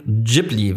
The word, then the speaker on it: Ghibli: Ghibli ist die japanische Aussprache mit einem stimmhaften J, das ich jetzt auch falsch ausspreche.